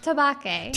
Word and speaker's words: Tabake: Tabake. – Tabake, exactly as it's spelled.